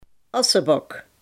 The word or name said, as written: asbak